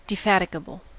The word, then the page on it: defatigable: [dɪˈfætɪɡəbəɫ]